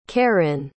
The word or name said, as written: karyn